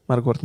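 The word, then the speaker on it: Marquard: się wymawia? Margard? – Margard. Media. I on powiedział coś takiego, że poziom cenowy utrzymywany na konkurencyjnym rynku polskich magazynów jest zdecydowanie zbyt niski.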